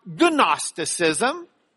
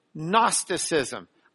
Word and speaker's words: Gnosticism: The G is silent. So it's not Gnosticism. It is just Gnosticism.